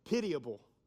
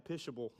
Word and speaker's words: pitiable: I didn't even know how to pronounce this next word. I had to go look it up. Pitiable. I wanted to say like pishable.